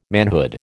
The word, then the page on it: manhood: American English